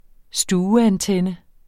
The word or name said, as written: stueantenne